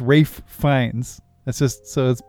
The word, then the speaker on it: Ralph: Rafe is they pronounce his name Rafe, so when you say it, it's what? Rafe Fiennes. Yeah, it's pronounced Rafe. A lot of people don't know that.